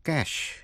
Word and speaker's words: cache: Brochure, brochure, B-R-O-C-H-U-R-E, brochure là cuốn sách mỏng. Cash, cash, cash, C-A-C-H-E là kho vũ khí hay là nơi cất giấu.